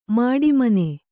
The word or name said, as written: ಮಾಡಿಮನೆ